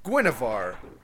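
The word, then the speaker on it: Guinevere: It's pronounced, apparently, Guinevar. – No. – I, for like, over time of saying it exactly how you did, over time, just started calling her Gunwaver.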